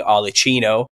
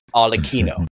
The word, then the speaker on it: Arlecchino: Kind of mash them together and you get what's known as Arlecchino, as it's pronounced in Italian. Someone's corrected me on this. I've been saying Arlecchino, but in Italian it's pronounced Arlecchino.